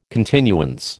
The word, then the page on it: continuance: American English